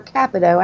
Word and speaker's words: Capito: Hey, Mike, and that's Shelley Moore Capito, actually. – Capito. I've been pronouncing that wrong forever.